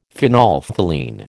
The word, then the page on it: phenolphthalein: American English